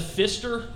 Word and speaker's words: Pfister: The Pfister Hotel? – Yeah.